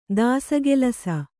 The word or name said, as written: ದಾಸಗೆಲಸ